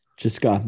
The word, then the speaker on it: CSKA: Cheska, yeah.